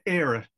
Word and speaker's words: Eire: Is it Eire or Eire? – Era. Era would be how you'd pronounce the EIRE, and the Irish vocabulary, the grammar of Ireland, it's quite a unique grammar.